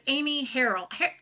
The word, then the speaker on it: Amy Harrill: I'm with Lady Powerhouse Solutions, and today I am here with Amy Harrill.